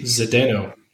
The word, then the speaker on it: Zdeno: Zdeno.